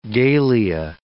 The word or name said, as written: galea